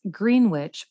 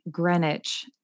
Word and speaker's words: Greenwich: Martha Moxley, she is from, which I originally thought it was pronounced Greenwich, but it's actually Greenwich, Connecticut. It's spelt like Greenwich.